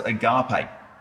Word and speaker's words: agape: The one here, it's pronounced agape.